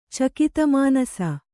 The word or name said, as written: ಚಕಿತಮಾನಸ